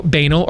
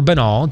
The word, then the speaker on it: banal: But that's why the talking points are so banal or banal. Depends on the day of the week how we're pronouncing that on the show.